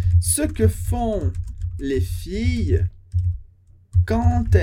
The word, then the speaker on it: quand: Les filles, les, as, filles, as meninas, as moças, as mulheres jovens, les filles, quand, quando, quand, atenção para a pronúncia, quand, sem pronunciar o dedo final, quand elles, quando elas, já falamos essa liaison, quand elles, quando elas são ou estão, nesse caso estão, amoureuse.